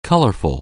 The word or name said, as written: colorful